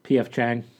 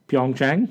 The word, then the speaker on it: Pyeongchang: P.F. Chang. – Stop it. How do you say it? – Pyeongchang.